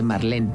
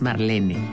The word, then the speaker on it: Marlene: o decir que tengo un Porsche en lugar de decir tengo un Porsche, porque en alemán Porsche se dice Porsche porque todas las es al final se pronuncian como Marlene Dietrich, por ejemplo, que todo el mundo dice Marlene, pero es Marlene. Entonces, si nos damos cuenta, no todas las marcas extranjeras o no todos los nombres extranjeros están en inglés.